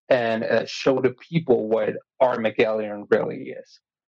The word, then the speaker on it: Armagelion: So, I've been pronouncing it wrong for 3 months, so. – It's okay. Armagelion.